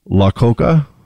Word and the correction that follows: Iacocca: Lee Iacocca is how you pronounce it.